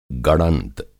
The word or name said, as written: गड़ंग